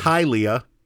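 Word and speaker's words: Hialeah: It is really disrespectful to Floridians that you didn't learn how to pronounce their name, their town name, before we started. – Hylia. – Yeah, Hylia. So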